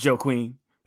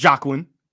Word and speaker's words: Joaquin: It's Joaquin. It's Joaquin.